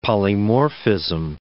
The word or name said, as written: polymorphism